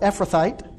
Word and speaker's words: Ephrathite: it highlights that he comes from a Ephrathite. I'm trying to put the emphasis on the right spot there, but Ephrathite, there we go.